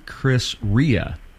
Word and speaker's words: Chris Rea: 27 albums, but he hardly, and it's actually Chris Rea. – Okay. – Is how he pronounces his name.